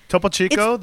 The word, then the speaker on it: Topo Chico: That fucking Topo Chico. Is that how you say it? – Topo Chico.